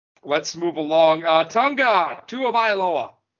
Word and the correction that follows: Tua Tagovailoa: Did I say that right? – No. Combined. – Tua Tagovailoa.